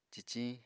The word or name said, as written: ciciñ